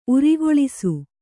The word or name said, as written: ಉರ್ಕಳಿಸು